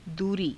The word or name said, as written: duurii